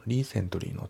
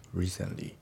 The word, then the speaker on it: recently: で、発音ですけど、リセントリーの T がね、抜けますね。 Recently。リセントリーじゃなくて、 Recently。